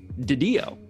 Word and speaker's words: DiDio: Fun fact about Didio, his last name is actually pronounced Didio.